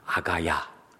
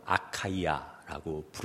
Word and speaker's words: 아카이아: 이남 지역을 다 가리켜 아가야, 미국식 발음으로 아카이아라고 부르게 되었습니다.